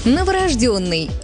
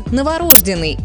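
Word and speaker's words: новорождённый: Очень привычно звучит «новорожденный» и настолько привычно, что ошибка почти не замечается. Но норма другая – «новорожденный» с ударением на «ё». И здесь как раз тот случай, когда буква «ё» не просто буква, а подсказка, она почти всегда ударная.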